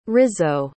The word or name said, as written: rizzo